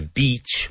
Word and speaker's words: beach: The beach, with a long E sound, the beach is green.